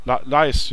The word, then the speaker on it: Laeisz: L-A-E-I-S-Z. Wie spricht man das aus? – L-A-E-I-S-Z. – Leischhalle? – Leis, ja, natürlich. Wie man es schreibt quasi.